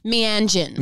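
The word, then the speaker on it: Meanjin: Tell me if I'm pronouncing it wrong, but Mianjin? – Oh, Mianjin. – Mianjin. – Mianjin. Other people say it differently and I'm not going to correct. – I'll take the way you say it. – No, you say it like that. Keep saying it that way.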